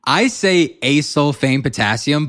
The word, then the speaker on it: acesulfame potassium: I say acesulfame potassium, but I've heard people say, right.